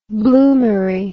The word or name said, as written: bloomary